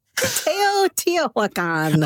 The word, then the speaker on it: Teotihuacan: Teotihuacan, the famous Mayan pyramids of Teotihuacan, opened this past week. Has anyone heard of this place? – No. What's it called again? – Teotihuacan. – Oh, Teotihuacan.